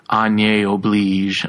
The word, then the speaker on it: Oblige: O-B-L-I-G-E, like oblige, but they pronounce it like Agne Oblige.